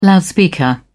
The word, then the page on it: loudspeaker: British English